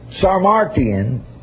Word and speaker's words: Sarmatian: Sarmatian is pronounced like carnation.